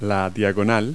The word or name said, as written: diagonal